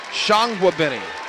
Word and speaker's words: Tsianguebeni: It's spelled T-S-I-A-N-G-U-E-B-E-N-I, but it's Shangwabini. It's like kind of French.